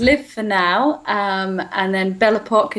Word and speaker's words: Belle Époque: You have something coming out called the acoustic album and Belle Epoque.